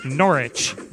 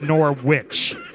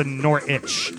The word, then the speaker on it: Norwich: it's Norwich, not Norwich. – Yeah. – It's not a Nor sandwich. It's a Norwich. Norwich.